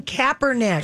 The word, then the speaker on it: Kaepernick: Colin Kaepernick.